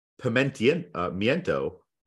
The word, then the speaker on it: pimiento: P-I-M-E-N-T-O, I believe. – Pimento cheese? – Yeah, there's pimento cheese, pimento loaf. – A pimento or, excuse me, I said that wrong. A pimiento